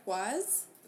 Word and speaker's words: Kwazz: On Twitter, I am at Jess Quaz, which is spelled K-W-A-Z-Z. And on Instagram, I am at Quazica, K-W-A-Z-Z-I-C-A.